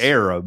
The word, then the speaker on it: Arab: For those who don't know, Arab, Alabama is spelled A-R-A-B, like yes. Arab, but of course we call it Arab around here.